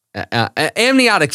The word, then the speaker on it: amniotic: Anatomic fluid. Amniotic fluid. God, amniotic. Yes. Amniotic fluid.